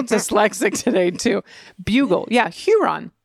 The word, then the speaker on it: Huron: Now, the beautiful submission from an ally in Port Huron is the new correction. It's Huron. – Huron. More of a long U sound, as in bulge.